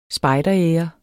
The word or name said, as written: spejderære